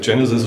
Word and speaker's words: Genesys: Also ich denke schon, dass die Idee dahinter ist, es als Genesis auszusprechen, als Schöpfung, auch wenn es sich mit Sys als System hinten schreibt. Ja. Insofern denke ich, Genesis ist schon richtig.